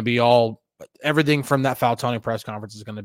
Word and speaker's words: Fautanu: Yes, that is the correct way to say his last name, Fautanu. He said it last night.